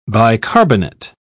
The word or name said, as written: bicarbonate